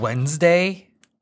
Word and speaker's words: Wednesday: Or like Wednesday. – There's the D in the middle.